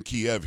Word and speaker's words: Kyiv: But instead of just going in there, he goes to the capital, which is pronounced Kyiv or Kyiv. I'm like, I don't speak Ukrainian, so I'm just going to say Kyiv. I know that we're saying Kiev here in the United States.